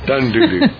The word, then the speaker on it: Dundudu: D-U-D-U? – Doo-doo. I'm sorry. Yeah. – Yeah. – That's right. – Dun-doo-doo. Or dun-oodoo. Dun-dun-doo-doo. Dun-doo-doo.